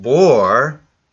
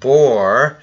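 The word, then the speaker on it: bor (בור): It's pronounced bor. I don't know how to spell it in Hebrew, but it's just bor, which means only a hole.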